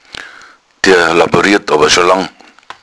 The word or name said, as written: laborieren